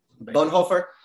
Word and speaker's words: Bonhoeffer: Bonhoeffer.